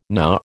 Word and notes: Na: American English

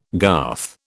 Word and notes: garth: British English

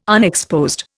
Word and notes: unexposed: Indian

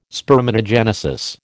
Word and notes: spermatogenesis: American English

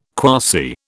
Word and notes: quasi: British English

